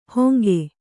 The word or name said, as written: ಹೊಂಗೆ